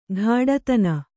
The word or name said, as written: ಘಾಡತನ